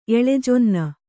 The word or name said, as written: ಎಳಜೊನ್ನ